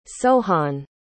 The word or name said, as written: sohan